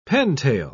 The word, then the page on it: pentail: British English